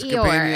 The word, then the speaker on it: Eeyore: It's Eeyore.